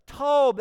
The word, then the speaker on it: Tob: Tob, that's the Hebrew word. You pronounce it like T-O-B-V, quickly, Tob, Tob.